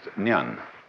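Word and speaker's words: nyan (念): It's pronounced nyan. In Japanese, nin.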